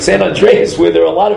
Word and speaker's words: San Andreas: San Andreas.